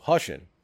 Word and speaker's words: Hushin: The next one, you can pronounce this one. I feel like I always say it wrong. The Hush. – Hushin. – Yeah, I don't. – Yes, it's Hushin.